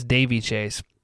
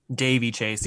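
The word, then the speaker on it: Daveigh Chase: I think it's Davy Chase. – Davy Chase.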